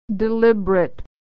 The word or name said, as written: deliberate